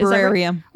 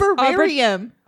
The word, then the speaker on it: arboretum: Arborarium. – Arborarium? – I like arboretum. – What the fuck is that? – I think it's arboretum.